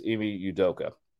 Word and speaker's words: Ime Udoka: So the head coach name is Ime Udoka.